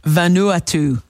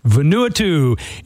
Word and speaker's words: Vanuatu: Vanuatu. – Vanuatu.